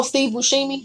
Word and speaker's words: Steve Buscemi: A ton of movies, but he has Steve Buscemi or something like that. – Oh, Steve Buscemi. – Yeah, there we go. I can't say his name.